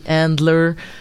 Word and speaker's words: Handler: tant méchants puis t'as The Adler qui est comme a l'air d'avoir quelque chose The Adler ou The Handler ça s'écrit avec un N.